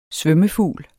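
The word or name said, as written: SVØMMEFUGLE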